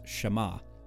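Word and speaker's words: Shema (שמע): Side note, the reason why it's called the Shema is because that first verb, hear or listen in Hebrew, is pronounced Shema.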